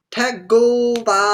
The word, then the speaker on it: Tagovailoa: Tua Tagovailoa? – Tagovailoa.